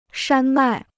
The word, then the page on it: 山脉: shān mài